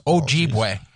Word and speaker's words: Ojibwe: That also includes the, I'm probably going to murder this. – Ojibwe. – I'm going to say that's probably the best pronunciation.